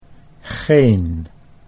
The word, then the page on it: geen: Dutch